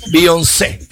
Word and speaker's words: Beyoncé: Es que trae acento en la E, al final. Beyoncé.